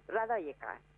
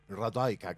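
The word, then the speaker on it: Radojka: Y con esta obra que vos antes decías, Radoska, estuvimos una vez en Rosario y había una señora serbia justamente y nos dijo Radoika. – Radoika, claro, porque la J se convierte en I, exacto. – Sí, exacto. – Sí, era como una pronunciación muy extraña. Para nosotros es Radoska, así en argentino.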